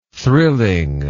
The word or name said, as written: thrilling